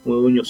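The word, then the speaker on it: Modugno: Moduño se pronuncia como Ñ, G-N.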